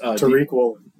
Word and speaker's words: Tariq Woolen: Hutchinson played great, and I can't say his name. So Seattle's Tariq Woolen.